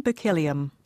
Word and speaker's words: Berkelium: Berkelium. Berkelium, yes. I, I, think it's Berkelium, that's the way I've heard it pronounced, but yes.